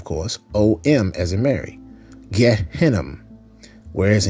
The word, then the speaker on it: Gehinnom: And Hebrew, it's Gehinnom, Gehinnom. We spell that in the English translation as G-E-Y,